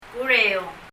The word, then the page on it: kureióng: kurɛi(y)ɔŋ